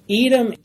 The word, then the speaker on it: Edom: Edom, it rhymes with, or it's a play on words with red.